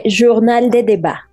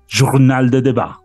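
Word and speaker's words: Journal des Débats: No sé pronunciarlo en francés. – Se dice Journal de Debats. – Journal de Debats. Muy bien, muchas gracias. Muchas gracias, nuestra profe de francés. Journal de Debats. Sí, lo hice bien.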